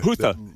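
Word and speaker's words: Houthi: The HOUT. Help me out. How do you pronounce that? – Hutha.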